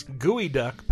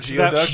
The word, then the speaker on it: geoduck: Well, correct pronunciation of this is Gooey Duck Powers. – Not Geoduck? – Geoduck. – They may have meant it to be Geoduck, but the animal is Gooey Duck.